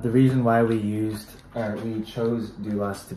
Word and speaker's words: doulos: So the name is Doulos, D-O-U-L-O-S. You want to start us off with this one? – Yeah. So the word doulos actually comes from the Greek word. It's pronounced the same way, doulos.